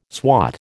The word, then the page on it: swot: American English